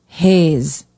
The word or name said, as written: hayes